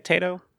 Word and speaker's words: Taito: Is that how you say it? Taito? – Yeah. Potato with the T.